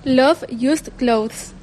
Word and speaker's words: Love Used Clothes: Jo veig que n'hi ha un lletrero ahí que no soc incapaç de pronunciar-lo, Love Used Clothes o algo així, com se pronuncia realment? – Love Used Clothes.